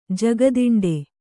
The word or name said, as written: ಜಗದಿಂಡೆ